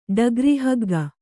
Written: ಡಗ್ರಿಹಗ್ಗ

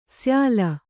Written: ಶ್ಯಾಲ

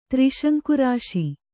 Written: ತ್ರಿಶಂಕುರಾಶಿ

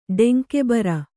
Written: ಡೆಂಕೆಬರ

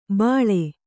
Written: ಬಾಳೆ

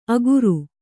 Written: ಅಗುರು

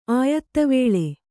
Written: ಆಯತವೇಳೆ

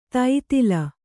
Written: ದತ್ತೆಲೆ

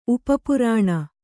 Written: ಉಪಪುರಾಣ

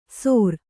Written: ಸೊರ

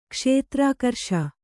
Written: ಕ್ಷೇತ್ರಾಕರ್ಷ

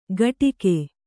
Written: ಘಟಿಗೆ

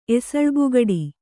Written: ಎಸಳ್ಬುಗಡಿ

